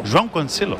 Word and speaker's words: João Cancelo: Lo he dicho bien. – João Cancelo. – João Cancelo.